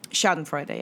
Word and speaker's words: Schadenfreude: And they talked about Schadenfreude. Is that how you pronounce it? – Schadenfreude, yeah.